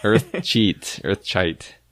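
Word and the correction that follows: Earthsea: arrive. – Earth Sea, that's right, Earth Sea.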